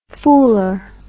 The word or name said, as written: fooler